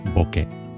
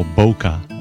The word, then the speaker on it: bokeh: Just put the bo and the ke together to make bokeh. It's not boke that rhymes with poke, as I say, or bokeh, as I've heard it said before. That rhymes with poker, or bouquet. Any other similarly mutated words are out as well. It's bokeh. The word bokeh comes from the Japanese word meaning to be senile, suffer from dementia, or to be fuzzy or blurred.